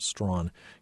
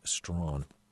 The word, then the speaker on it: Strachan: By the way, if you go and look for the book, his last name is spelled Strachan, pronounced Strawn, Hugh Strawn.